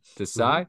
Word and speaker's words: Desai: Now, I don't know if I'm saying this right, the last name, Sean Desai? – Desai, mm-hmm.